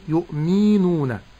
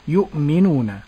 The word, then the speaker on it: يؤمنون: فأسكن الهمز ولا أحركها لأنها واقعة بين متحركين، فلا أقول يؤمي. – هذه قلقلة. – نعم. – نعم وهذا نطق خطأ. – نعم. لكن أقول يؤمي كسر الميم، ولا أقول مي، ولا أقول يؤمنون بمد الميم.